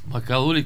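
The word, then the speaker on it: Макалу: Макало ли как се казва? Ударението. – Точно така.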